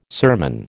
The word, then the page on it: sermon: American English